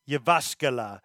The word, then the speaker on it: Jyväskylä: So it is Jyväskylä.